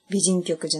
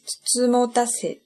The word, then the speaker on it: 美人局: 実は美人局じゃなくて、つつもたせって読むんです。大家可能觉得应该读作美人局，其实呢，它读作つつもたせ。結構覚えにくいと思うんですけど、啊。读作字母大赛，我觉得还蛮难记的一个词，我到现在还记不住。えー、つつもたせとは、